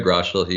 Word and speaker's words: Groeschel: I think it's Craig Groeschel is his last name. – Yeah, is that how you pronounce it? Yeah, Craig Groeschel.